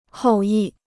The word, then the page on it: 后裔: hòu yì